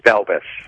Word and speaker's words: Balbes: It's Balbes.